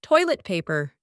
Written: toilet paper

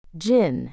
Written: djinn

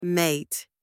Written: mate

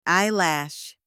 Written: eyelash

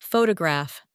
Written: photograph

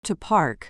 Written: to park

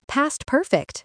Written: past perfect

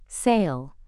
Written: sail

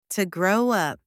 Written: to grow up

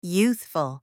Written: youthful

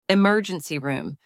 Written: emergency room

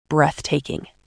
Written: breathtaking

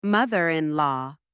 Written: mother-in-law

